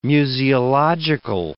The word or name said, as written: museological